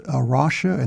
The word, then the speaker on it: Arocha: And again, the ministry that he is the board chair of is Arosha, and that's spelled A-R-O-C-H-A.